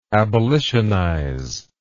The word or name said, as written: abolitionize